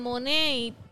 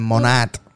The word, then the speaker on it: Monat: Yo no me quito en Monet porque... En Monat. Monet. – ¿Cómo se escribe? ¿Cómo se escribe? – M-O-N-A-T.